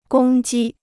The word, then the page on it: 公鸡: gōng jī